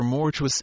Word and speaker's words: mortuus: But if we ask the perfect of mortuere, we get the regular answer mortuus est, with a W. For thus mortuus is pronounced, like fatuus, arduus, conspicuous, and similar words, which are not perfect participles but adjectives, and are declined without regard to tense.